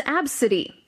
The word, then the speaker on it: Abcde: This is Absidy, but her name is spelled A-B-C-D-E.